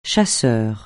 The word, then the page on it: chasseur: français